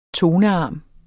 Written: tonearm

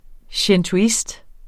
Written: shintoist

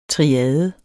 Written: triade

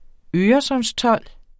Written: øresundstold

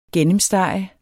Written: gennemstege

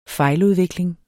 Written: fejludvikling

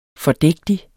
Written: fordægtig

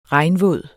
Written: regnvåd

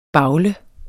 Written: bavle

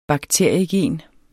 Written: bakteriegen

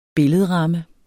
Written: billedramme

